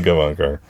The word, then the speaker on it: Gavankar: I can't pronounce that right Gavankar. Because I'm so happy. – Janina Gavankar. Yeah.